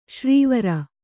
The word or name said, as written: ಶಾರ್ವರಿ